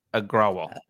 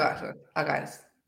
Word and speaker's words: Agrawal: I don't know how to pronounce this, but so I might be butchering it, not intentionally. – Agrawal. – What? – Agrawal.